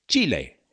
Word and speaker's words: Chile: All right, that was Chile, or as the locals say, Chile.